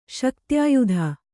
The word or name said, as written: ಶಕ್ತ್ಯಾಯುಧ